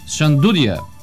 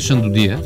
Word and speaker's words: шандудия: Или както я наричат там, сега ще ме извиняват кюстендилци и хората от региона за ударението, шандудия. Не е шандудия. Шандудия. Дуда. Шандудия.